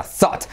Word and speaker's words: thought: Z, sonido Z, como zapato en España, Zaragoza, thought, ¿vale? Y fíjate cómo te digo que se pronuncia TH, o se escribe T-H-O-U-G-H-T. Thought.